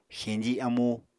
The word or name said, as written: xeñji amú